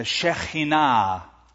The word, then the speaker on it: Shekinah: It's called the Shekinah, or a better pronunciation, the Shekinah, a visible reminder of the glory of God.